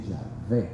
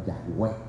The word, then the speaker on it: Yahvé: Hay que decir Yahvé, no Yahweh.